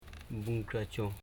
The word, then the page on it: bunkracho: bun.kra.cho